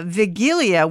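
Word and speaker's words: Wigilia: So the Vigilia, which is pronounced with a W, but the W sounds like a V, there's going to be refreshments, I guess, from 4 to 6.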